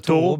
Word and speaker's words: Thoreau: Réussi à le prononcer, mais on a le droit de dire Thoreau, très bien, comme un taureau.